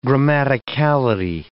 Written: grammaticality

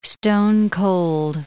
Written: stone-cold

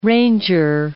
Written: ranger

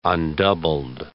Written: undoubled

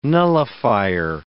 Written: nullifier

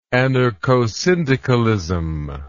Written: anarchosyndicalist

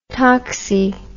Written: toxy

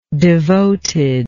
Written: devotedness

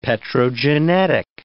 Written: petrogenetic

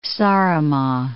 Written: Saaremaa

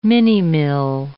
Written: minimill

